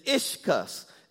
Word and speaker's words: ischus: And it is ischus. It's spelled I-S-C-H-U-S.